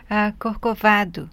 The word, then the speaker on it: Corcovado: Corcovado, from the Getz Gilberto record. Did I say that wrong again, Astrid? – Corcovado. Okay. The just the accentuation was in the wrong syllable then, so.